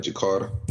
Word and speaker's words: Jakarta: Jakarta, Indonesia. – Yeah, you said it right, Jakarta.